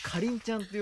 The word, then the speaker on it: かりん: 乃木坂 46 の伊藤かりんちゃんという子をぜひ紹介したいなと。これ大事なのはやっぱまず発音の時点で、はい、かりんちゃんではないですね、うん、みりんとかキリンの発音のかりんちゃんなんですよ、はい、これでも結構昭和の顔やで、この子。